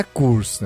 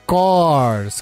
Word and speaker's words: Coors: O Juliano mora nos Estados Unidos e ele explicou pra gente que a pronúncia da cervejaria Coors não é Coors, né? A cervejaria do grupo Molson Coors, né? É Coors, que nem Doors.